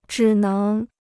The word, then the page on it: 只能: zhǐ néng